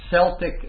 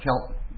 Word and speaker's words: Celtic: Well, the Galatians, these people who lived in Galatia, were people that had come from Celtic invaders or Celtic. You know, I always have trouble with this. Celtic or Celtic, because I've heard it pronounced both. So I say Celtic.